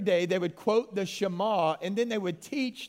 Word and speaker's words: Shema: Now, you almost got to say it like you're coughing up something. Shema.